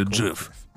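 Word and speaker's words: GIF: Если ты изначально объяснил, что это значит и как ага. это произносить, но ведь это, это уже ушло в народ и широко использовалось до того, как стало известно, что на самом деле Джефф.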